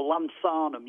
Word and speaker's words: lanthanum: Okay, it's it's lanthium, and I'll spell it for you, or lanthanum. You might get it as lanthanum, actually. L A N T H A N U M.